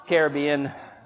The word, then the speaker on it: Caribbean: I mean, Caribbean, you know. – Oh, just the Caribbean. And you say it correctly. Well done.